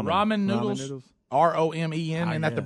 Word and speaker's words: ramen: Oh, just like Roman, but it's ramen. All right, you should R-A-M-E-N.